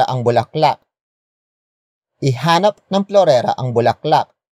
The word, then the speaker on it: bulaklak: It is the focus marker that introduces the main topic of this sentence, and that is bulaklak. Bulaklak. Bulaklak. Bulaklak is spelled as B-U-L-A-K-L-A-K.